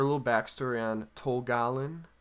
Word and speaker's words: Tol Galen: And they go and live in, Tol Galen. Is that how you say it? – Yeah, yeah.